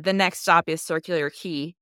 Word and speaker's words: Circular Quay: Every time you say Circular Quay, I go, I was mispronouncing it. I was constantly calling it Circular Quay the whole time. So I'm like, that's how you pronounce it.